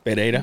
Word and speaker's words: Pereira: Michael Pereira. – Okay, so. – Pereira.